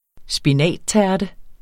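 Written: spinattærte

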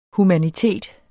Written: humanitet

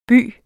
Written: by

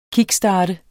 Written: KICKSTART

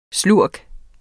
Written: SLURKENES

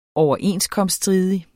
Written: overenskomststridig

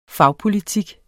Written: fagpolitik

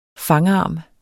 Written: fangarm

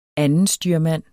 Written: andenstyrmand

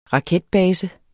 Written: raketbase

